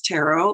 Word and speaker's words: tarot: And every once in a while, it's really important to remember most people pronounce tarot like carrot.